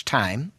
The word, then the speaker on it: thyme: A tablespoonful of fresh thyme. – Big tea, fresh thyme. – I have a question. Why do we pronounce it thyme when it's spelled T-H-Y-M-E?